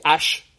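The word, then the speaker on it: Æ: Eh, en inglés, es como ya lo dije, X-A-E, el A-E se pronuncia ash, y luego viene una A, un guión y un 12.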